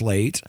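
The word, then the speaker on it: Leight: This piece was written by Elias Late. Mm-hmm. You know, we talk about Elias a lot. He's written some really great pieces. I think you pronounce his last name Late.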